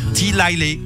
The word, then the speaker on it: Delilah: Hein, donc voilà, Plain Whiteys, Hey There Delilah ou Delilah. Parce que si on le prononce en anglais.